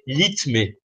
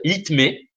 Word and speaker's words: litmé: Mais ben, si vous faites à ce moment-là, le premier code, c'est litmé. Ça, s'écrit L-I-T-M-E. Si vous dites trois fois le code litmé, alors on va le dire trois fois ensemble, hein, litmé, litmé, litmé.